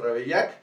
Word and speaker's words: Ravaillac: Fíjate, en 1610, en París, François Ravaillac, ¿lo dije bien? ajá. Eso, Ravaillac.